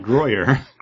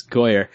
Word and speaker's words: Goyer: It's not Groyer. – Groyer. – Goyer. – It's Goyer.